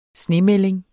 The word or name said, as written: snemelding